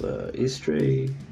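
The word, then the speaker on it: Austri: Which are actually pronounced Istre, Vestre, Norstre, and Soothstre.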